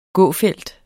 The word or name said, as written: gåfelt